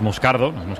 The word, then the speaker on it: Moscardo: Como bien ha pronunciado, es Moscardo, brasileño, sí, sí.